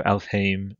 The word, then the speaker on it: Alfheim: and apparently we've been saying Alfheim, and like we go the I'm, but it's actually Aim, so Alfheim, Nilfheim, you know, so figured I'd mention that.